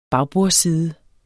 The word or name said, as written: bagbordsside